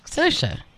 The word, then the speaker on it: Saoirse: But we were told by Saoirse Ronan that it rhymes with... – Who are you talking to, white girl? – It rhymes with inertia, like Saoirse.